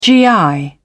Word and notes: gi: British English

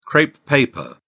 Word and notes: crepe paper: British English

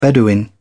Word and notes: bedouin: British English

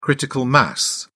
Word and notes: critical mass: British English

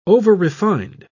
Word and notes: overrefined: British English

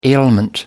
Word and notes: ailment: American English

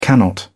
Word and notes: cannot: British English

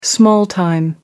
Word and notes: small-time: American English

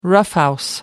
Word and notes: roughhouse: American English

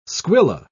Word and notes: squilla: British English

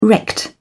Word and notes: wrecked: British English

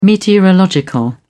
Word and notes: meteorological: British English

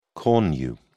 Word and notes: cornu: British English

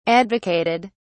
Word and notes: advocated: British English

